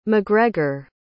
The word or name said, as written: mcgregor